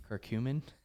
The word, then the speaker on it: curcumin: Cucumin. – Curcumin, lemon balm, and zinc.